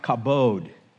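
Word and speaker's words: kabod (כבוד): The Hebrew word glory literally means weight. It's pronounced kabod.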